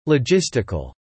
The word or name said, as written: logistical